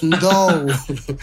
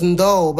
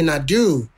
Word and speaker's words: N'dou: And my last name is pronounced N'dou. It's pronounced N'dou, but everybody here calls me N'dou.